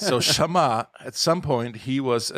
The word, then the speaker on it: Shammah: His name is Shammah. He's mentioned maybe just once or twice. He was one of the bodyguards of King David's guard. That's the right way to say it.